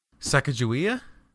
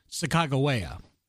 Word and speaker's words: Sacagawea: So that is what we know as that's a pronunciation that we've all grown up with. But due to scholars and Native Americans or American Indians who are from the same original tribe, the actual proper pronunciation is Sacagawea.